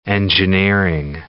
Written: engineering

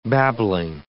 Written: babbling

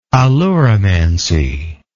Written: aleuromancy

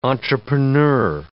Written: entrepreneur

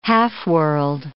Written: half-world

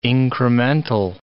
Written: incremental

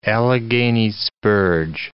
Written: Allegheny spurge

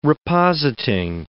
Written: repositing

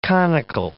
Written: conical

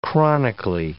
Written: chronically

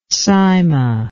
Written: cyma

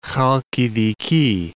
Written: Khalkidiki

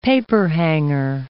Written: paperhanger

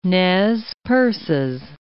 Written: Nez Perces

